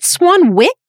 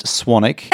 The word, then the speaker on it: Swanwick: Is it Swanwick? – It's pronounced Swanick.